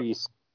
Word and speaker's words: Rhys: has hit it out of the park, and his name is Paul Rise. He's a Welshman, obviously, with the last name Rise. – Actually, R-H-Y-S. – R-H-Y-S, yep. – Yeah, it's yep. Reese. – Reese, okay, that's how you pronounce it.